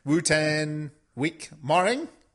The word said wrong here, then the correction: Wotan Wilke Möhring: The W's are all V's. – Ah. – And the E's at the end of the words are pronounced usually.